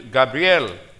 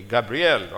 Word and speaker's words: Gabriel: In the sixth month, God sent the angel Gabriel. I'm pronouncing it Spanish way or Hebrew way. Gabriel or Filipino way. You Americans say Gabriel.